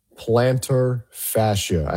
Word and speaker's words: plantar fascia: PJ Locke tore his plantar fascia.